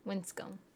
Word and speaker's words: Winscombe: The E is silent. – Well, then why is the B there? – Winscombe. – Okay. – It's like comb.